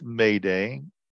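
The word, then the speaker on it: m'aidez: It's like M apostrophe AIDEZ Maydez. – Yeah, them French people spell things funny. – But it's pronounced Mayday,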